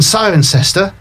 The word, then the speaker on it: Cirencester: Because Leicester... And Cirencester, same spelling, totally different pronunciation.